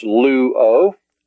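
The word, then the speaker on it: Lu-o (λύω): Then the next word is luo. It's pronounced just like the first luo. Lambda, omicron, upsilon, with the omega on the end. Lu-o.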